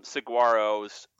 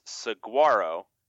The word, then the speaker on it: saguaro: and he was correcting me to say that the G is mostly silent there, which is correct. It's more like saguaro, but whatever.